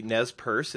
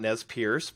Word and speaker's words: Nez Perce: Yeah, speak of pronunciation, obviously Nez Perce, it's not Nez Pierce, but I still hear that, including on one of the local radio programs.